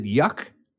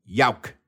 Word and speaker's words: Yauch: Y-A-U-C-H. – Mm-hmm. – Some people pronounce it yuck or yowick, mm-hmm. – but it's actually Yauk, Adam Yauk of the Beastie Boys.